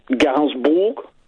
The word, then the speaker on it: Gainsbourg: Gainsbourg.